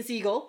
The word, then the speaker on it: Segel: there's Jason Segel or Jason Segel? Wait. – Jason Segel. – Yeah. Yes. Yeah.